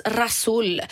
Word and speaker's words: ghassoul: G-H-A-S-S-O-U-L, lera från mm. Zagora. – Fast det uttalas rasul.